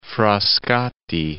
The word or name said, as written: Frascati